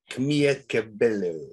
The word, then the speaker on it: Camila Cabello: Camila Cabello. – I have no Spanish heritage whatsoever. I cannot pronounce the double L's properly.